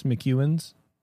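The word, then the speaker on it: McEwan's: How do you spell it? – M-C-E-W-A-N. Apostrophe S, McEwen's.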